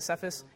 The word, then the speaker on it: Cephas: All right, is that Cephas? Mike, how do you say that? Is it Cephas? Yeah, it's Peter.